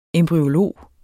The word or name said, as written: embryolog